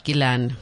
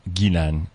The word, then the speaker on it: Ghislaine: But they pronounce it Ghislaine. – And this shit, Ghislaine, right.